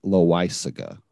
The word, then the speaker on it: Loaisiga: I got to ask, is that how you pronounce it? So it's Loaisiga? – Jonathan Loaisiga is how that is pronounced.